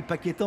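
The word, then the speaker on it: Paquetá: Quelle déception, Paqueta s'appelle Paqueta en brésilien.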